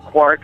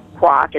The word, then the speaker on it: Quark: But it's, you know, I'm sure you are aware what quark or quack, it depends on how you want to pronounce it, is.